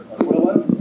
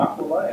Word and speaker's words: Aquila: Now we have examples of this in Acts 18, 1-3, where Aquila, as I've always said it, or Aquila, as Benny says it, and as I believe the correct pronunciation is, they provided services for Paul.